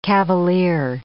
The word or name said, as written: cavalier